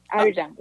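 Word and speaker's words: Arirang: Is it Ari Rong? Ari? – That's correct. – It is good. – That's correct. – Okay. – No, Ari Rong.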